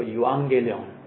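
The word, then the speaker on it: Evangelion (εὐαγγέλιον): 那个发音叫做 Evangelion，你们猜猜是什么英文字？是我们教会的英文名 Evangel。所以我们的教会的名字叫福音，我们不叫 Gospel Bible Presbyterian Church，我们叫 Evangel，或者你用希腊文来说，我们的教会叫 Evangelion Bible Presbyterian Church。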